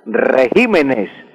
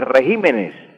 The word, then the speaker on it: regímenes: Entonces el plural de régimen sería regímenes. Los regímenes de gobierno, un error muy común en la prensa no pronunciar correctamente o miedo a decir regímenes. Lo correcto, el plural de régimen es regímenes.